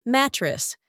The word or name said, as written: mattress